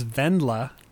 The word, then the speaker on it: Wendla: And I pronounced Rachel's character, I pronounced Leah Michelle's character in Spring Awakening as Wendla, but it is Vendla. Because it is a German name,